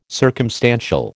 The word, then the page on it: circumstantial: American English